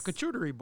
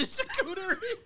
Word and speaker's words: charcuterie: A charcuterie board.